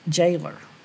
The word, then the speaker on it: gaoler: G-A-O-L-E-R. – Gaoler. – It's jailer. – It's what? – Jailer.